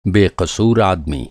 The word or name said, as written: बेक़सूर